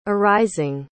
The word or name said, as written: arising